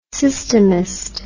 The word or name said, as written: systemist